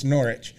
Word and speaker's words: Norwich: It's Norwich.